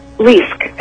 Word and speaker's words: Leask: Leisk, L-E-A-S-K, and it's with a long E.